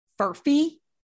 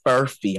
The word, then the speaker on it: furphy: Furphy? – Furphy. I'm going to spell it for you. F-U-R-P-H-Y. Furphy.